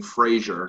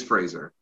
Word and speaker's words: Fraser: And as Elijah will definitely say multiple times, Ryan Fraser, because that's the American way to say it. But it is Fraser.